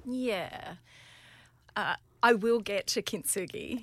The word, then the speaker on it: kintsugi: And a concept that you work at with your coaching is this idea of kintsugi. Did I pronounce it correctly? – Yeah.